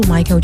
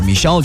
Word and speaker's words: Michael: 可以这么认为， Billie Jean 帮助 Michael Jackson 奠定了 International Pop Icon 的地位。 – She was more like a beauty queen from a movie scene. I said don't mind, but what do you mean? I am the one who will dance on the floor and around. – Billie Jean from Michael Jackson，有高三英语老师的话叫 Michelle Jackson。